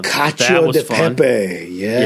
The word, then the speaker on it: cacio: Yeah, cacio. Yeah, I can never pronounce it. Uh, but I made that. Um, Two C's together, a generally a C, gen, gen, generally a CH.